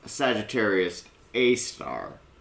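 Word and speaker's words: Sagittarius A*: And let's talk briefly about the name, because it is pronounced Sagittarius A star. But that star is actually an asterisk.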